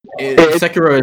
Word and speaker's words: Sekiro: Wait, am I saying it wrong, Sekiro? – Sekiro is closest to the correct pronunciation.